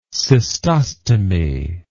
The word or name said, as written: cystostomy